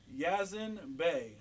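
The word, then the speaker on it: Yasiin Bey: It's hard to pronounce, but Yazin Bey.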